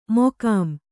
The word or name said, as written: ಮಕಾಂ